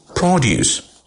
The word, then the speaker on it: produce: If you stress on the final part of the word, produce, it becomes a verb. If you enunciate or stress on the first syllable of the word, it becomes a noun.